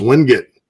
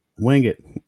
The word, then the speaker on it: Wingate: Carolina Empire says, we got to work on your pronunciation of Wingate. It's pronounced Wingit. – Wingit.